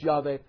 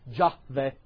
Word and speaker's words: Yahweh: because sometimes it's Yahweh and sometimes it's Jahweh. But remember that there is no such term, there is no such sound as J in the Hebrew language. It's the Hebrew letter Yod, pronounced like a Y.